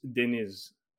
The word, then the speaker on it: Deniz: Not to turn it into like a whole lesson here, but Turkish names, the accent is always on the second syllable, and so it's Deniz. So you know, I don't know, like it's not that important. – Deniz.